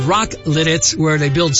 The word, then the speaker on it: Lititz: L-I-T-I-T-Z.